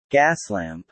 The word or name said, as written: Gaslamp